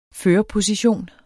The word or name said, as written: førerposition